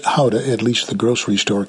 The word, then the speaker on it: Gouda: The locals call it Houda, not Gouda.